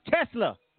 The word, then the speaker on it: Tesla: Yeah, that name is pronounced Tesla, like the cars.